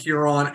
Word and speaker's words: Huron: But, you know, give us a story about Huron, how it was named, and then just a quick history.